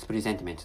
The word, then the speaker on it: presentiment: but actually the correct pronunciation of presentiment is presentiment. The S is pronounced as Z.